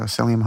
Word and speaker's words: psyllium: Right, it was made with cream cheese, eggs, psyllium husks. Did I say that right? – I think so, psyllium husk.